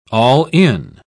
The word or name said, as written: all-in